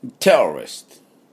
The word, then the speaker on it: terrorist: で、このテロリストの音はですね、 Terrorist、 Terrorist というところに、なります。で、イントネーションは手のところです。